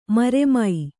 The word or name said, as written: ಮರುಮೈ